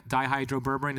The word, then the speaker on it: dihydroberberine: dihydroberberine, is that how you say it? – Yeah, exactly.